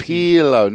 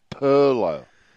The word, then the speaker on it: Pirlo: Well, what is Pirlo, not Pirlo.